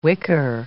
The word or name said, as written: wicker